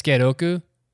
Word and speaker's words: Sukeroku: Sukeroku? I can't remember how you say it. – I think it's Skeroku. – Skeroku, yeah.